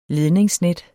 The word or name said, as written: ledningsnet